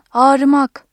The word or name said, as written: ağır-